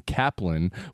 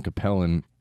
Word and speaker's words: Cappelen: which is spelled C-A-P-P-E-L-E-N. My best friend in the world, Ryan Capellan, and his brother Stevie and Rusty are the Capellans. Sebastian told me they pronounce it Kaplan, which is Danish because Sebastian is from Denmark and we played today.